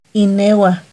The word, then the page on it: inehuah: Náhuatl